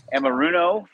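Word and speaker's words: Anarumo: But as of right now, yeah, they just interviewed Lou Amoruno from the Cincinnati Bengals. I'm going to respect him and say his name correctly there, unlike our broadcast last night.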